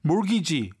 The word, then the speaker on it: mortgage: 그리고 mortgage, 이게 원래 주택장기대출, 주택융자 이럴 때 쓰이는 단어인데 T가 발음이 안 돼요. 알았죠? mortgage.